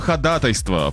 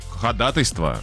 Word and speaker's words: ходатайство: Слово «ходатайство» правильно произносят в основном только юристы. Ну да, слово «договоры». – Ходатайство. – Ходатайство. – Ходатайство. Ходатайство. – Да, правильно, ходатайство.